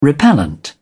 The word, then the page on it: repellent: British English